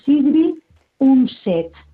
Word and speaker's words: Sigrid Undset: Lo pronuncio como, porque es noruega ella, o sea, ¿sí? – Pero se debe de pronunciar así, Sigrid Undset.